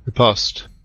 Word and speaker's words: riposte: And it follows quickly with a ripus. How do you pronounce that? – Riposte. – Riposte. Thank you.